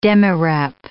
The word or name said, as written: demirep